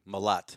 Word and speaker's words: Malott: Malat. – Malat. – Yes. M-A-L-O-T-T. They spell it wrong and they call me Mallet.